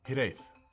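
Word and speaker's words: hiraeth: If that H is silent, boy, man, Hirith. Hirith. Do I have to put an accent on it? – You got it right. You rolled the R.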